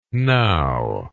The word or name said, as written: knowe